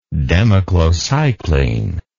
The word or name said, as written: demeclocycline